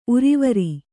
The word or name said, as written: ಊರ್ವರೆ